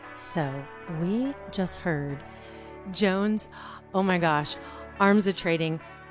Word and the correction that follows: Armatrading: Rush, did I say it correctly? No. Armis. – No way. – Armatrading. Okay.